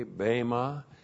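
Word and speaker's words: bema (βῆμα): B, long E, M, A, bema.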